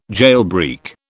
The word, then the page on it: jailbreak: British English